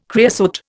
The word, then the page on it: creosote: Indian